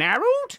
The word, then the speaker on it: Harald: Now, his first name, Harold, is spelt with two A's, so it's more like an Harold.